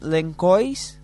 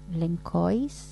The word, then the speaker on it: Lençóis: Y el lugar de las dunas se llama Lençóis Maranhenses, pero escribís Lençóis. – Lençóis. – Claro, porque la C es una C cedilla, se llama en Brasil.